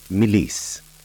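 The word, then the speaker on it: mélisse: Lemon balm in French is pronounced Melisse and spelt La, L-A, Melisse, M-E-L-I-S-S-E.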